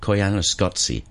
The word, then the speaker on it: Koyaanisqatsi: Koyaanisqatsi. But it's spelt S-Q-A-T-S-I. So there's no Q.